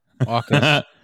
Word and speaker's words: AUKUS: AUKUS.